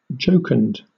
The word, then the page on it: jocund: British English